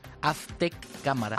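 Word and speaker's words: Aztec Camera: ¿Pero la Z cómo la pronuncias? – Aztec Cámara. – Aztec Cámara. – Cámara, sí. – Con la pausa entre las dos, ¿no? – Efectivamente, sí.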